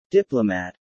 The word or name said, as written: diplomat